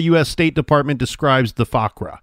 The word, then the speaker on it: Fuqra: Fakwa, And I'm not saying that right. F-U-Q-R-A, Fakra.